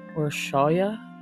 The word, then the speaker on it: Orsolya: I looked her up on Facebook and noticed that she had a pronunciation of her name, and it is pronounced Orsolya on there. So I'll be pronouncing it that way throughout this podcast.